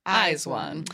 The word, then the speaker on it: IZ*ONE: That's how you say it. Eyes One.